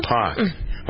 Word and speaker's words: Deepak: I'm full of inspiration today because last night I went to a lecture by Deepak Chopra. Actually, I got to pronounce that correctly. I spelled his name incorrectly earlier. D-E-E-P-A-K. It's Deepak. – What did you think it was? – I put P-O-K.